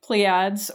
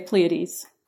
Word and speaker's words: Pleiades: And I also just want to point out to listeners that there are two ways to pronounce the word that we're saying. You can either say Pleiads, or you can say Pleiades. So we're both right.